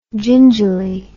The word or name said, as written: gingili